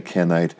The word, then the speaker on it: Kenite: But, you know, when you're using that I-T-E suffix, right, you basically have some word and then you stick ite on it, right? You have K-E-N, you have Ken. Okay, you're sticking ite on it. But it's not K-E and you're sticking night on it, like Kenite.